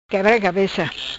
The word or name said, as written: Cabeca quebra